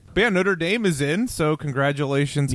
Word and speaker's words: Notre Dame: But it's Alabama, Clemson, Notre Dame, and Oklahoma. – Thank you for pronouncing Notre Dame in the Irish way.